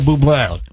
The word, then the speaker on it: Bublé: Isn't that how you say it? – Michael Bublé. – Michael Bublé. Because it's got the apostrophe on the end of it.